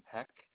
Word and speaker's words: Pecht: Addison Peck. That's spelled last name P-E-C-H-T.